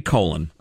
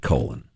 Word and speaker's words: Colin: I believe uh, Colin James Hay, the leader of Men at Work, the fine Australian band, pronounced it colon although I could be wrong.